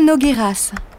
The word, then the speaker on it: Nogueiras: Paula Nogueiras. – Comment ça s'écrit, Nogueiras ? – N-O-G-U-E-I-R-A-S.